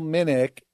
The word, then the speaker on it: Mynyk: So we're speaking with Daniel Minnick, and that is spelled M-Y-N-Y-K.